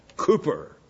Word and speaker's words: Cowper: William Cowper, as the Americans like to call him, Cooper, actually, as his name in English is supposed to be pronounced. Why they spelled it C-O-W for Cooper, I don't know.